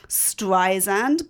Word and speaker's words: Streisand: I had no idea that you're meant to go Streisand, Barbara Streisand, all my life. Because, you know, I'm like one of those people. I'm like Streed, Stupid, Australia, Barbara Streisand.